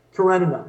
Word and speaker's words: Karenina: Am I pronouncing that right? – I think you're talking about Anna Karenina. – Karenina, there you go.